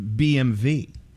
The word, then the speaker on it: BMW: The last letter isn't pronounced like a W. They pronounce it BMV.